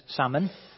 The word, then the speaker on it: Salmon: Spelt same as salmon, but Salmon is the way that I like to pronounce his name.